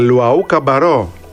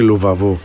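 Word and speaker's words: Luwawu-Cabarrot: Αφού το βρήκες, φίλε, για Λουαού Καμπαρό, έτσι όπως προφέρεται, και όχι Λουβαβού.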